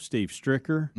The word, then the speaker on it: Stricker: Is it Stricker? Just like it's spelled? – Yeah, Steve Stricker.